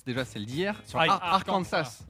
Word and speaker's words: Arkansas: Non, on dit Kansas, mais Arkansas. – Arkansas.